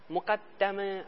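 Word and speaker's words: مقدّمه: مشهور اینه که این واژه به کسر دال تلفظ میشه، گفته میشه مقدمه. مقدمه از جهت ادبی صحیحه، منتها مشهوره از قدیم الایام.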